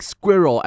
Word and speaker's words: SQuRo: It somehow sounds like squirrel as in an animal, right? But no, it is spelled as S-Q-U-R-O, Squirrel, okay?